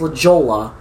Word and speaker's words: La Jolla: And it's not La Jolla, okay? It's not pronounced La Jolla. It's pronounced La Jolla. – It's a Spanish.